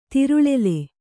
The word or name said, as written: ತಿರುಳೆಲೆ